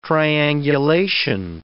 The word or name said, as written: triangulation